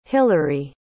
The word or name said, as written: hilary